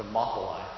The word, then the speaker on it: Thermopylae: Thermopylae. – It, Thermopylae, thank you, where 300 Spartans held out, you know, a battle against tens of thousands of Persians.